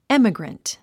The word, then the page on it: emigrant: émiɡrənt; エミグラント